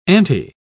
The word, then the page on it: aunty: British English